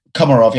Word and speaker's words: Komarov: Komarov, yeah, absolutely.